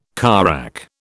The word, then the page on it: carrack: British English